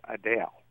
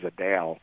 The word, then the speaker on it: Adele: She did she was doing a Q&A with fans, and one of the fans, you know, addressed her as Adele with the emphasis on the second syllable. – Yeah. – And she and Adele said, "I love that." She said my name perfectly.